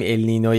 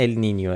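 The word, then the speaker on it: El Niño: بله به هر حال اینو همه اول بگیم که اگه دیدید یه بار میگیم ال نینو یه بار میگیم ال نینیو، علتش اینه، ولی تلفظ درست اگه میخواین ال نینیو، ولی همه جا تو فارسی به چرخید کسی چیکی نمیفته ال نینیو، همه جا نوشتن ال نینو.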